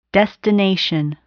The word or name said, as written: destination